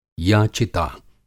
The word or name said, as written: याचिता